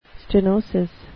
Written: stenosis

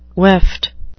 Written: weft

